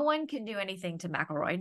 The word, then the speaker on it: McElroy: It looks like McElroy, but it's pronounced McElroy.